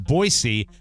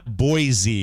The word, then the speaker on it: Boise: It's pronounced Boise, not Boise. – Oh, I definitely say Boise.